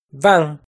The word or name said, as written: Vaaj